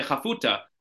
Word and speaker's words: Chafuta: And his name is Rabbi Chafuta. I went to watch a video to make sure I pronounced his name correctly. That's how he says it, Chafuta, even though they spell it here Chaputa.